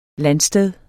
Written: landsted

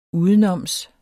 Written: udenoms

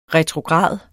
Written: retrograd

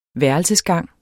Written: værelsesgang

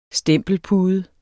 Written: STEMPELPUDER

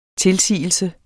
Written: tilsigelse

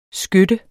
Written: skytte

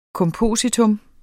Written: kompositum

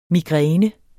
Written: migræne

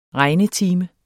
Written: regnetime

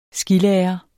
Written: skilærer

